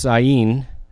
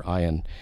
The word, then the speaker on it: Ayin: It's pronounced Ayin, or Ayin, or Ayin, depending on which Hebrew scholar you listen to. I prefer Ayin.